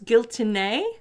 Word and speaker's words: Giltinė: I think her name is pronounced Giltinė, because mm-hmm. there's a little accent over the E. It's spelled Giltinė. And like I said, there's a little accent. – Mm-hmm. – So I believe it's Giltinė.